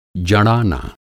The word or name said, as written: जड़ाना